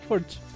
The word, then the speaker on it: Forge: Ah, ah, Forge. Forge. Forge. Forge. Como la forja.